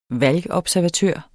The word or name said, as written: valgobservatør